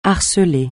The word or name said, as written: harceler